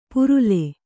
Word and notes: ಪರಲು: paralu

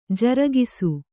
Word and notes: ಜರಗಿಸು: jaragisu

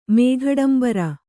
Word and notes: ಮೇಘಡಂಬರ: mēghaḍambara

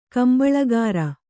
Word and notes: ಕಂಬಳಕೋರಿ: kambaḷa kōri

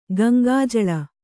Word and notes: ಗಂಗಾಜಲ: Gaŋgā jala

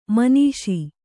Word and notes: ಮಾನುಷ: mānuṣa

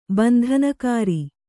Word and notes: ಬಂಧನಕಾರಿ: bandhanakāri

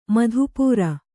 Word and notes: ಮಧುಪೂರ: madhu pūra

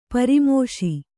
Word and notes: ಪರಾಮೋಶಿ: parāmōśi

